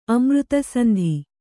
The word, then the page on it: ಅಮೃತಸಂಧಿ: kannada